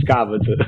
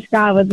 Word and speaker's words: Scavas: Perché leggevo scavese, no? Scavese. Poi dopo quando ho letto la traduzione che è scalza, ho detto no, ma questo è mio dialetto, quindi Scavas. – Esattamente, Scavas con l'accento sulla... Esatto, l'hai detto benissimo.